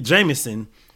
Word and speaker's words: JMSN: So my pick for this week is actually going to be Jameson. His name is spelled J-M-S-N.